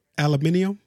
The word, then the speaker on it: aluminium: You want to say it like the British people? Aluminium? That's what they say? – No.